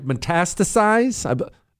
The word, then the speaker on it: metastasize: I can never say it right. Metastasize? Nailed it.